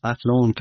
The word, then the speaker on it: Athlone: founded by Aidan Bond and some colleagues in Athlone, Ireland in 2002. Is that, am I pronouncing the town right? – That's right, yes. Athlone, County Westmeath, Ireland.